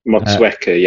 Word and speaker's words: Motueka: Dry hopped with Sabro Mateka, is that how you pronounce it? – Motueka, yeah.